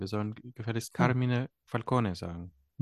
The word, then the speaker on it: Falcone: Dass Falcone hier Falcone ausgesprochen wird und nicht Falcone, wie es noch in Batman Begins ähm, der Fall war.